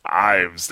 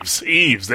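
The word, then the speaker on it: Yves: Actually, the relationship between Ubisoft and Nintendo in regards to the Wii, because Ives. That's what we're calling him, right? That's what we said last time when I tried to say his name. Eves, there we go.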